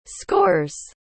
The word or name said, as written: scores